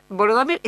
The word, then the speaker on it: Volodymyr: ¿Quiere decir Volodymyr? ¿Es otro nombre de Vladimir? – Y es la pronunciación ucraniana.